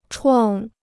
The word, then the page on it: 创: chuàng